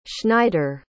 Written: Schneider

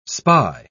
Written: spy

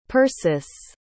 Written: persis

